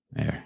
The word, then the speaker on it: Mayer: Mayor, Mayor. but not like the mayor that runs your town into the ground. The mayor that's like Oscar Minor Meyer Wiener. No, like Travis fucking Mayor.